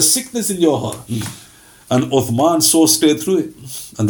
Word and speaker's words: Uthman: They were pronouncing the names properly. Uthman.